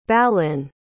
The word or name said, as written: balin